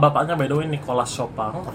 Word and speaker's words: Chopin: Kalau ikut nama bapaknya kan itu kan French makanya Chopin. Tapi kalau karena dia memang lahir dan besar di Polandia, orang Polandia tuh bacanya Chopin. – Oh, Chopin. – Nah, tapi kalau orang Perancis Chopin.